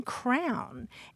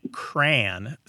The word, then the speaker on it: crayon: And then there are people who say cran, sounds like rhymes with the first part of cranberry, mm-hmm. – rhymes with man.